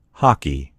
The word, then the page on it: hockey: American English